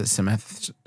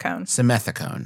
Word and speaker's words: Simethicone: Simethicone. – Simethicone.